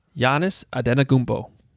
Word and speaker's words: Antetokounmpo: Say it again. – Antetokounmpo? – No, I think close. I added a T there. – Giannis Antetokounmpo.